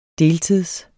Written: deltids

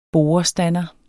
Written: borestander

